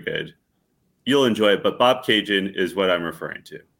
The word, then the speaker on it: Bobcaygeon: And one of their best songs is a song called Bob Cajun, which is about a small town about 2 hours northeast of Toronto. If you want to look it up, it's spelled B-O-B-C-A-Y-G-E-O-N.